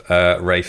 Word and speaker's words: Ralph: There's a particularly brilliant scene with Ralph Fiennes as a Ralph Fiennes. – That guy, he is. – Most people get his Christian name wrong, not his surname.